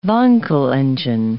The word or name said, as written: Wankel engine